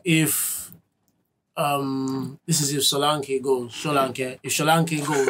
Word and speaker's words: Solanke: I've never heard anyone say it like that. – Yeah, it's Solanke. – He's Nigerian.